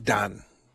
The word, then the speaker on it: Dan: So this is the tribe of Dan, or of Don, D-A-N, would have been pronounced Don.